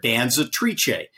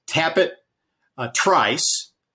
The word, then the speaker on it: Danzatrice: So it's by Tappet, obviously, out of a mare that ran for Steve Asmussen that was owned by Gainesway Farm that was named after the Italian word for female dancer, Danzatrice. That's the official correct pronunciation of the mare, Danzatrice.